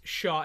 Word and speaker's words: Chau: Okay, you heard that C-H-A-U is pronounced Shaw in game, so thank you so much, Leon Sandcastle.